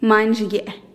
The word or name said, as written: main zhigye'e